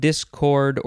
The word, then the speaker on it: discord: You can emphasize either syllable, discord or discord. Intransitive verb from the 14th century. The synonyms are disagree and clash. It's from the Latin discordare, which is from discord or discourse, which means discordant.